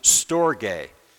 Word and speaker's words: storge: It might look like the word storage, but I'm missing a letter, but it's actually pronounced storge.